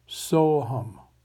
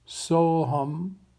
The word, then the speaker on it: Soham: Soham is pronounced like our English words so and hum put together. The whole word is pronounced Soham. I'll say that two more times. Soham, Soham.